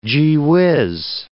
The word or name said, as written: gee whiz